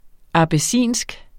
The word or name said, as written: abessinsk